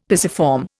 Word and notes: pisiform: Indian

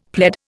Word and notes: pled: Indian

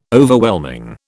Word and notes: Overwhelming: British English

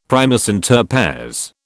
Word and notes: primus inter pares: British English

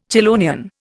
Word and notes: chelonian: Indian

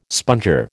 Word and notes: sponger: American English

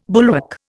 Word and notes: bulwark: Indian

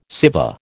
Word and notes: Siva: American English

